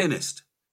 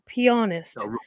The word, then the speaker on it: pianist: So I got castigated online this weekend for pronouncing that word exactly that way. – Right. – And apparently we ought to be saying pianist, because if we say it the other way, it sounds like the male appendage.